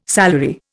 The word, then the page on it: salary: Indian